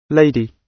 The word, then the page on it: lady: British English